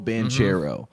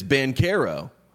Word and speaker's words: Banchero: And it's still sticking with me because the entire media landscape prior to Tuesday night called the star of Duke Paolo Banchero, which is the American way to say the name. Now, he is half American, half Italian. We love half Italians here on this program. And the Italian way to say his name is Banchero. And that is what he is going with.